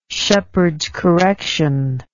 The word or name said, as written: sheppard's correction